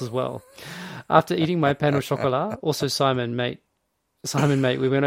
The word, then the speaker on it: pain au chocolat: How to pronounce pain au chocolat.